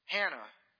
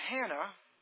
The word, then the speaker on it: Hannah: Actually, in the New Testament, she is called Anna, because in the Greek, in that construction of that word, the H is silent. But we meet a Hannah in Luke's gospel.